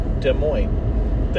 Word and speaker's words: Des Moines: I was like, no, it's just Des Moines. The S's are silent.